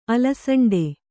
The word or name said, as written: ಅಲಸಂಡೆ